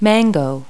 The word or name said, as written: mango